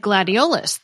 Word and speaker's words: gladiolus: Like gladiolus, the last syllable in that is a schwa, right? – Gladiolus indeed is a schwa at the end, yep.